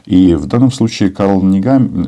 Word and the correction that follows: Нехаммер: Нехаммер, наверное, правильно таким образом произносить его фамилию, он, э, он же был в Киеве буквально на днях.